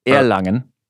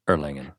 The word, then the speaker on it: Erlangen: And the Americans like Erlangen is how you will spell it, but Erlangen. – Erlangen.